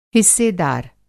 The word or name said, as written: हिस्सेदार